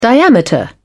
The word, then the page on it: diameter: British English